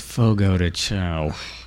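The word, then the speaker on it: Fogo de Chão: It is pronounced Fogo de Chao.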